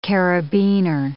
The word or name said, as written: carabiner